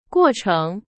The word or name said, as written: 过程